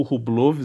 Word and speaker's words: Rublev: Ah, para terminar, Chardy ganhou do Rublev, ou Rublov, se você preferir a pronúncia russa original.